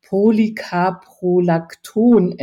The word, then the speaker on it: Polycaprolacton: dass der Filler Polycaprolacton enthält. Ich hoffe, ich habe das jetzt richtig ausgesprochen. – Super richtig ausgesprochen.